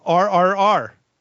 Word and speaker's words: RRR: Yes, it's RRR.